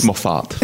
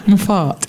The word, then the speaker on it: Moffat: It's Moffat. – Moffat. Anyway, so they have written something brilliant.